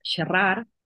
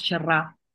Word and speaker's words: xerrar: En Cataluña, por ejemplo, los verbos pronuncian la última consonante, nosotros no. Por ejemplo, dirían ellos xerrar, nosotros xerrar no hacemos el infinitivo, nos comemos la R, ¿no?